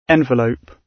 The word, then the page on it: envelope: British English